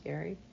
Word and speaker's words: Gerry: Gary writes G-E-R-R-Y, by the way, Barker. Yes, it's pronounced Gary.